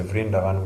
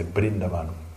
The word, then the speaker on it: Brindavan: Because in Bengal we pronounce V, we pronounce B. – Na Bengala, os Vs pronunciamos os Bs. – We don't say Vrindavan, we say Brindavan. – Dizemos Brindavan em vez de Brindavan. – So we don't say Vraj, we say Braj.